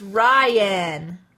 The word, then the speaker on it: Ryan: He said Rian. It's Ryan. Like Ryan? – Like Ryan.